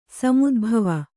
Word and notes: ಸಮುದ್ಭವ: samudbhava

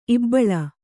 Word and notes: ಇಬ್ಬಳ: ibbaḷa

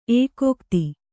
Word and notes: ಏಕೋಕ್ತಿ: ēkōkti

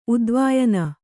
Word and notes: ಉದ್ವಾಯನ: kannada

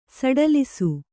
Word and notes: ಸಡಿಲಿಸು: saḍilisu

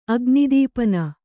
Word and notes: ಅಗ್ನಿದೀಪನ: agnidīpana

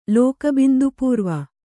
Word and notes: ಲೋಕಬಿಂದುಪೂರ್ವ: lōka bindu pūrva

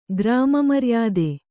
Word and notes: ಗ್ರಾಮಮರ್ಯಾದೆ: grāma maryāde

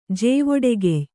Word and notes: ಜೇವೊಡೆಗೆಯ್: Kannada

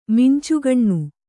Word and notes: ಮಿಂಚುಗಣ್ಣು: Kannada

